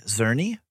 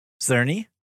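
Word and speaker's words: Czerny: I did like seeing, I'm going to butcher his last name, but Henry Zerny. – Try it again. – Zerny. – I liked it the second time.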